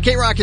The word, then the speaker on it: KROQ: K-Rock, we'll be back after this. – I really did up this time. Deny my dear. Deny my dear. – 106.7 K-Rock is K-R-O-Q, and it is 8:38.